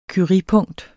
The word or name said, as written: curiepunkt